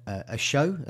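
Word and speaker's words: Osho: a show, I think he is pronounced at in defence, and Horvath in goal.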